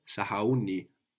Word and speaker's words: sahauni: Sahauni. And it moves the accent. No. Sahau. Sahauni. We always have the accent on the penultimate syllable, the second last syllable in Swahili.